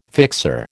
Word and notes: fixer: American English

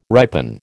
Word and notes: ripen: American English

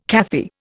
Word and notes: Kafir: Indian

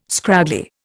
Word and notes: scraggly: Indian

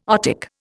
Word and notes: otic: Indian

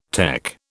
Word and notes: tec: British English

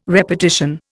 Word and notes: repartition: Indian

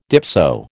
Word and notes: dipso: American English

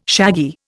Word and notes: shaggy: Indian

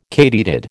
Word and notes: katydid: American English

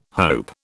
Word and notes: hope: British English

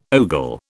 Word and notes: ogle: British English

